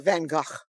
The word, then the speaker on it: Van Gogh: Van Gogh. Anyway. – We're Dutch. We have to pronounce it the right way.